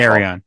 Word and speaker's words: Arion: Paul Kupperberg told me how to pronounce it once, and I've already forgotten. I'm sorry. – Arion. He says it as Arion.